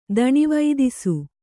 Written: ದಣಿವೈದಿಸು

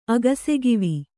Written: ಅಗಸೆಗಿವಿ

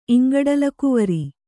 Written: ಇಂಗಡಲಗುವರ

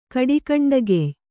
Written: ಕಡಿಕಂಡಗೆಯ್

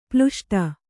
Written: ಪ್ಲುಷ್ಟ

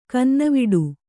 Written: ಕನ್ನವಿಡು

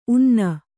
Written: ಉನ್ನೆ

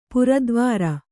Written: ಪುರದ್ವಾರ

